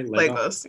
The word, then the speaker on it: Lagos: Lagos, yeah.